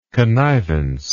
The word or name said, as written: connivently